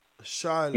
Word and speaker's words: Charlo: It's Charlo. It's not Charlo. – Charlo. – His name is Charlo. – Charlo. – Yeah, he told us how to pronounce his name. It's Charlo because it's French.